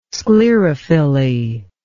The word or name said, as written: sclerophylly